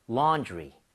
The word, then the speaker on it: laundry: 이것을 바로 laundry라고 하는데 A-U-A-W는 발음할 때 O예요? 어예요? 어에 더 가까워요. 완전 어는 아니지만 이 모양을 O보다는 벌려야 됩니다. 그래서 laundry. laundry가 아니에요. 자, 가봅니다. laundry.